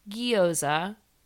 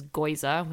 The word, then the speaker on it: gyoza: They are the pork gyoza. That's how you say it. My boyfriend says goiza, which is absolutely wrong, but the pork gyoza, I boil it in water, and then I put, listen to it.